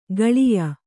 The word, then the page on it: ಕಾಳೇಯ: kāḷēya